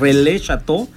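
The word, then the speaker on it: Relais Châteaux: Es una certificación que se llama relais, difícil pronunciarlo como ellos, pero es relais chateau,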